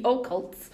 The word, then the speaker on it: occult: In the occults. – Am I saying it wrong? – Occult. – Occult. Occult. – Occult. It's like O-Town.